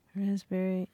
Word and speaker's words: raspberry: K-N-E-E-L, Neil. – Raspberry, answer. – Silent letters. – Yes, silent letters. Oh, yeah. Raspberry is the silent S. – P. – Well, P. Sorry. – Silent P, right?